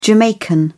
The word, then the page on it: Jamaican: British English